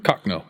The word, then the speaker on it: Cochno: Cochno. Yeah, Cochno stone.